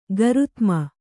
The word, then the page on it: ಗರುತ್ಮ: garutma